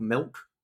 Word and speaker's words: Milque: It intensely annoys me that Milk, his name is spelt M-I-L-Q-U-E and not just Milk, M-I-L-K.